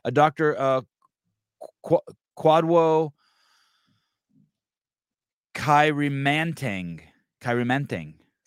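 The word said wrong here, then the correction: Kwadwo: So it's Quadjo, but you did well with the second name. – Quadjo, with a J kind of, Quadjo? – Yeah, like that.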